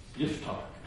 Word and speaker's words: Yiftach: And I believe it was the same thing he was desiring for Israel back in the days before he rose up, this judge, Yiftach. That's the way to pronounce it in Hebrew.